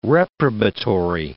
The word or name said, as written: reprobatory